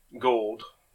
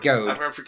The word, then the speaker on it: Goad: And again, there's this really disturbing moment with Gold. – Yeah. – Or Goad. – Goad.